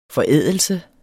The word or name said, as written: forædelse